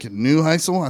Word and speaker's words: Neuheisel: For Diggs, I have Rick Neuheisel. I think I'm saying that right. – You are.